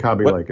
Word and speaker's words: Kaby Lake: Last week, everybody announced their new stuff with, I think it's pronounced Kaby Lake. I always called it Kaby Lake, but I believe it's Kaby Lake chips. – I think Kaby Lake is correct, by the way.